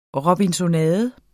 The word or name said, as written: robinsonade